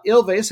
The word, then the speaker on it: Ilves: Uh, Ilves have it better on uh, Ilve, I should say. The S is silent.